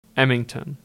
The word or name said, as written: Emington